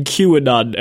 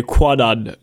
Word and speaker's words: Kwannon: You're also doing a very good job not saying QAnon every time you say Kwannon, just to well, let you know.